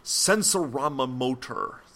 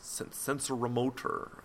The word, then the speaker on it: sensorimotor: The sensorama motor, sensorimotor.